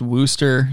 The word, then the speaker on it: Worcester: Kyle says it's like Worcester, Mass. Is pronounced Wooster.